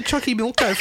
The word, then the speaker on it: Xochimilco: Just say Xochimilco from now on.